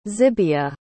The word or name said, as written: zibia